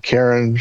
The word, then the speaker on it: Charon: So before we get too far into this one, I want to ask you, do you pronounce it Charon or Charon? – So I usually pronounce it Charon, but sometimes Charon, sometimes hey you, sometimes, no, don't take my coins.